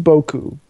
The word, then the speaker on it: Boku: They entered into a partnership, there we go, with the mobile payment system, I assume this is pronounced Boku, B-O-K-U, as a way to buy digital products and manage the PSN wallet.